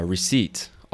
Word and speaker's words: receipt: Receipt. R-E-C-E-I-P-T. And the P is silent.